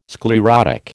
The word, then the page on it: sclerotic: American English